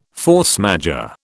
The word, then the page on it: force majeure: British English